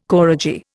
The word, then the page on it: koradji: Indian